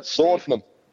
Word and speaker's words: laudanum: Well, what was Loudon? What Loudon? Is that how you say it? – Laudanum. Laudanum.